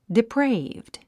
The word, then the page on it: depraved: dipréivd; ディプレイブドゥ